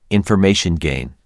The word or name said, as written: information gain